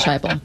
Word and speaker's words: Heupel: Josh Heupel, final answer. – Yeah, Josh Heupel.